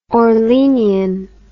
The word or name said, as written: orleanian